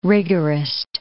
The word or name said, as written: rigorist